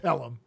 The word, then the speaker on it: Pelham: It's actually the taking of phlegm 1, 2, 3. – Oh. Yeah, I know what you're saying. – It's pronounced Pelham.